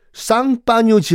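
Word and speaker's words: Champagne: 그러니까 샴페인을 프랑스어 발음으로 하면 쌍파뉴거든요.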